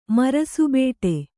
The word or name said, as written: ಮರಸುಬೇಟೆ